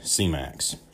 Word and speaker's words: Semax: So first peptide I want to talk about is CMAX, and it's spelled S-E-M-A-X, and it's definitely falls more in line with like an nootropic.